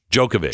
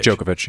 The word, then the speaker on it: Djokovic: Djokovic. – Djokovic, yeah. – It noted that Djokovic was such a problem because he became an anti-vaccine hero. Moderna's report stated the optics of wild with Djokovic.